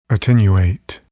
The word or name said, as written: attenuate